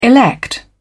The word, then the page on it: elect: British English